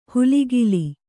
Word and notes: ಹುಲಿಗಿಲಿ: Kannada; huligili

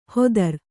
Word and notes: ಹೋತೃ: kannada

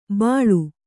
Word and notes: ಬಾಳು: bāḷu

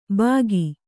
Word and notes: ಬಾಕಿ: kannada

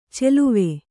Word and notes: ಚೆಲುವೆ: Kannada

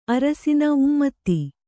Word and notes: ಉಮೇದ: Kannada

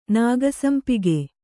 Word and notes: ನಾಗಸಂಪಿಗೆ: kannada

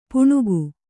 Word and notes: ಪೆಣಕೆ: kannada

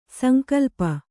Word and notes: ಸಂಕಲ್ಪ: sankalpa

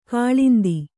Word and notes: ಕಾಲಿಂದಿ: kālindi

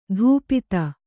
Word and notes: ಧೂಪಿತ: kannada

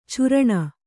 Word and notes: ಛುರಣ: churaṇa

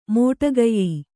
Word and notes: ಮೋಟಕೆಯ್: mōṭa key

